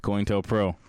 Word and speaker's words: COINTELPRO: COINTELPRO. – Mm-hmm. COINTELPRO.